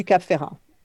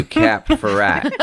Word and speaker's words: Cap Ferrat: So if I'm saying it, how do you say it in French? – Grand Hotel du Cap Ferrat. – Or the Grand Hotel du Cap Ferrat.